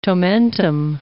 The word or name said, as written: tomentum